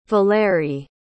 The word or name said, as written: valéry